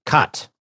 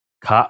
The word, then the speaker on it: Cait O'Riordan: Uh, no, not Kate. Cot. Cot. Oh, it's Cot. Yep, that's how you pronounce it.